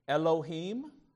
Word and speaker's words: Elohim: Elohim.